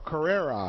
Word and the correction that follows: Correa: I hope I pronounced that right. Correa. I'll get it eventually. – Correa.